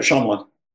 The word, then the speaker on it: Shyamalan: Truly, I always butcher his last name, M. Night Shyamalan? – Yeah, you got it, Shyamalan.